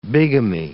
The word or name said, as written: bigamy